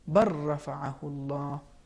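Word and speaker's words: بَل رَّفَعَهُ اللَّهُ: اس کی دوسری مثال ہے بل رفعہ اللہ، تو ادغام کی صورت میں یہ تلفظ ہوگا بر رفعہ اللہ، سورہ نساء.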